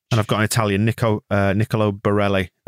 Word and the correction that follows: Barella: uh, Barella, sorry, He's there for you, Moscow.